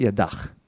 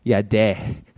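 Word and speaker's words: yada: It's actually not yadach. It's yadeh. I know, it's so different, but it's actually yadeh.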